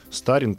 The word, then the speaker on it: старин: живучесть, я так понимаю, да, живучесть этих вот старин, да, старин, как правильно, на ударение ставить. Все правильно. Да.